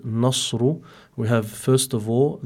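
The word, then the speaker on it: nasru (نصر): So be careful, make sure that the noon is muraqqaqa, so that you pronounce it as nasru.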